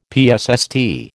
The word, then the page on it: psst: American English